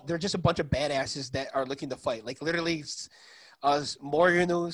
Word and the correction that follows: Minoru: How do you guys pronounce his name, guys? – Minoru Suzuki.